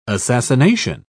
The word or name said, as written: assassination